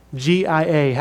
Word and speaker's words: noctalgia: The new word is spelled NOCTALGIA.